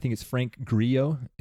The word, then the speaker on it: Frank Grillo: Frank Grillo is the way you say his name.